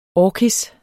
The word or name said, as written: orkis